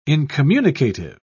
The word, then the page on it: incommunicative: British English